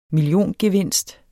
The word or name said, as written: milliongevinst